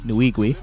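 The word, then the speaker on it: Nwigwe: His name is Toby Nuigui. That's I looked up the pronunciation. Nuigui.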